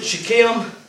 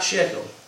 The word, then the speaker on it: Shechem: Some people pronounce it Shechem, but it sounds to me more like Shechem.